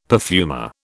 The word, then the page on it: perfumer: British English